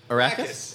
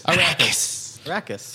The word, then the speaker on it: Arrakis: How do you say the Arrakis? Arrakis.